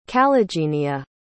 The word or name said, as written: calligenia